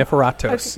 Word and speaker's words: Niforatos: Niforatos.